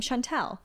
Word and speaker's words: Chantel: But she, my mom doesn't even pronounce my name like that. Yeah. Chantel. – Chantel. – And I never introduce myself that way. Yeah. So I say, hi, I'm Chantel. With, I guess the inflection is on the tell.